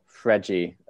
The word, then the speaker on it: Fred: but I think Fred or Fredgy, as I think Brazilians pronounce it for Manchester United,